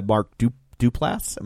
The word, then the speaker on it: Mark Duplass: She has a brother played by Mark Duplass.